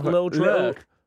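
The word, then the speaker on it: Lil Durk: D-U-R-K. How would you say that? Little Dirk.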